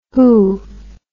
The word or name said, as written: hoo